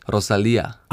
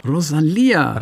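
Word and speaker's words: Rosalía: Rosalia, and I'm not actually sure about the pronunciation of that. – In her song, she says, Rosalia. – Oh, yeah. Rosalia. – Rosalia.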